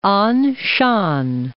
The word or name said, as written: Anshan